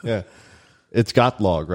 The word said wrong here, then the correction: Tagalog: It's Tagalog.